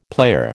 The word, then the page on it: player: American English